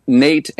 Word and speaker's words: Nate: I'll tell you, but my name is Nate, N-A-T-E, and then Pickowicz, P-I-C-K-O-W-I-C-Z.